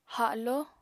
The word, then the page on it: haló: apatani